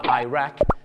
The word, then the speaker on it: Iraq: ชาวอังกฤษจะเป็นอิรักกับอิหร่านแต่ชาวอเมริกาเยอะมากครับจะออกเสียงว่า Iraq กับ Iran